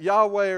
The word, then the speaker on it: Yahweh: I don't want to offend anybody, but it's taking those four consonants, dropping in the vowels from Adonai, and you get Yahweh or you get Jehovah out of it. And that's where it comes from in terms of our English pronunciation.